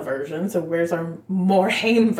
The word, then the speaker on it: Haim: It's Haim.